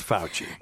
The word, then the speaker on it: Fauci: Fauci. That's right. – Okay. Well, I heard people say it different. – Well, so. It's Fauci.